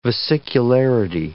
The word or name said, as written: vesicularity